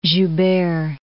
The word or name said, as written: Joubert